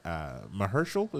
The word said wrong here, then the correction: Mahershala: is that how you say his name? – Mahershala.